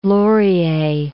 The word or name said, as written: Laurier